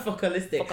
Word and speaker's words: Focalistic: And then also, like, even, like, with the acts, like, because it was, God forgive me, I don't know how to pronounce it. Folkloristic. – Folkloristic. – That's it, yeah. Wow, you said that good. Say again. – Focalistic. How can I forget?